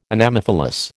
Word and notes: Anemophilous: American English

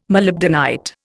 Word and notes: molybdenite: Indian

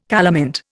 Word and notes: calamint: Indian

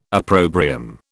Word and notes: opprobrium: British English

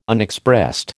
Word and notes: Unexpressed: American English

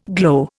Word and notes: glow: Indian

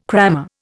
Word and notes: crammer: Indian

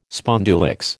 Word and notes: spondulicks: American English

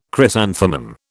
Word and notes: chrysanthemum: British English

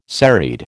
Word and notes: serried: American English

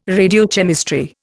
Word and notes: radiochemistry: Indian

